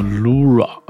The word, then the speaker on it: LVRA: 在今天节目的最后，我们听到的是来自一个华裔的苏格兰音乐人，他的原名叫做 Rachel Lu，然后他的这个英文名字应该是叫做 Lura 的这个时候 Anxiety 焦虑。嗯，这是他的名字，我看啊，应该是读作 Lu Lunoa Lunoa 啊，就是 L O O R A 对，但是他这个拼写是 L V R A 对。